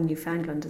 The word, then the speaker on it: Newfoundland: Yeah, I've got to rewrite a play that's opening a theatre festival in Newfoundland, or Newfoundland as we pronounce it,